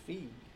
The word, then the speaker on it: Feig: How do you pronounce it? – It's Feig.